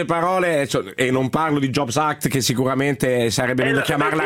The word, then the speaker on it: Jobs Act: perché dicevano job acts, mettevano la S in fondo, invece è jobs act.